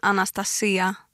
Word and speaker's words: Anastasia: Ja, om jag ska på grekiska så säger man Anastasia med betoningen på i-et då.